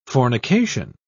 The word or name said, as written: fornication